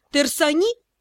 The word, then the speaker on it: Терсани: Так что свое «Терсани» с ударением на второй слог Тин не озвучивала вовсе.